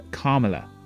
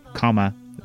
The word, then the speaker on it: Kamala: which is Kamala, like a comma, Kamala, Kamala.